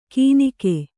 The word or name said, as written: ಕೀನಿಕೆ